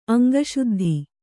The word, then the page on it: ಅಂಗಶುದ್ಧಿ: Kannada